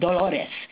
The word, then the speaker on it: Dolores: My name is Dolores Garcia, and it's spelled like the Latin word for pain or sorrow. So any of you who took Latin, it's Dolores, D-O-L-O-R-E-S Garcia, G-A-R-C-I-A. My email address is Garcia, G-A-R-C-I-A, dot Dolores, D-O-L-O-R-E-S, at DOL.gov.